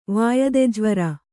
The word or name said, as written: ವಾಯದೆಜ್ವರ